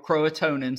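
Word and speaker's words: Croatoan: And the word Croatan, or Croatonin, some people pronounce it, was carved into a tree trunk.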